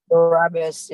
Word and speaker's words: Barabbas: He writes, we have become like the crowd chanting Barabbas. Is that how you say it? – Barabbas, yeah.